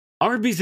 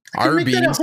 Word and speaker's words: Arby's: Arby's. So Arby's is phonetically just R and B spelt out, and it stands for roast beef.